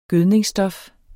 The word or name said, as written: gødningsstof